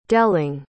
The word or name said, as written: delling